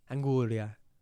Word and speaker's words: anguria: Or anguria. And I don't like when people call it anguria. – Why? – Because cocomero is the best word. – Cocomero. – Cocomero. – Cocomero. – Mm, good. – Anguria.